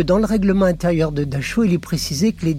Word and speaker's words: Dachau: Il va s'évertuer à prononcer le nom du camp à la française. C'est-à-dire qu'en allemand, c'est Dachau. Mais lui dira toujours, et il précise même en mouillant la deuxième syllabe, Dachau.